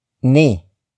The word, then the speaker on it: ni: The next word is ni. Ni. Ni. Ni is spelled as N-I.